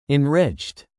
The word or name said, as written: enriched